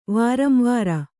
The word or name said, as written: ವಾರಂವಾರ